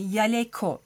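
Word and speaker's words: jaleco: Fakat o baştaki J, Y gibi okunuyor. Ve Yaleko diye seslendiriliyor.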